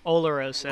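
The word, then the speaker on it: Oloroso: And Delarosa, Demarosa? – Olorosa. – Olorosa, fuck.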